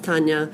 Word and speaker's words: Tanja: Well, my name is spelled T-A-N-J-A, and it's pronounced Tanya.